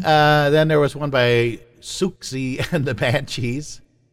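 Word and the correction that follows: Siouxsie: I think it's Susie and the Banshees, actually. The X is silent.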